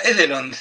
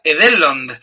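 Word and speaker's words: Edhellond: No se pronuncia Edelond, se pronuncia Edelond.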